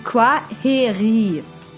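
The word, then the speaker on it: Kwaheri: Quaheri. – Quaheri? – Ja, genau. Das I spricht man länger hinten und das KW vorne wie ein Qua.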